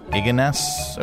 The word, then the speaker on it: Ignace: Ignace.